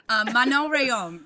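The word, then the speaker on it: Manon Rhéaume: Oh, you were so close. Actually, you did not butcher it as bad as other people do. Usually they're like, Manon Rium. And I'm like, oh, everybody just stop. Manon Rium.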